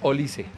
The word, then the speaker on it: Olise: Tal, la correcta pronunciación es Olise.